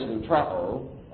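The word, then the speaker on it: lutrao (λυτρόω): One is lutrao, that's how that's pronounced, to release on receipt of a ransom.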